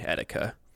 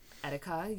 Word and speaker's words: Etika: I had never really watched Etika or Etika. I don't really know how to pronounce. – I think it's the second way, Etika. – Etika, yeah.